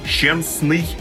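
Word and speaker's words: Szczęsny: Именно так звучат и в русском языке тоже польские фамилии. Так что, пожалуйста, Щенсный, Ковалевский, Шиманский, Левандовский. Нет, Уэйн Грецки, здесь никакого экранного не надо, он канадец. Так что будем произносить правильно, тем более, что я уверен, фамилия Левандовского скоро начнет звучать еще чаще и звонче, но если это вообще возможно.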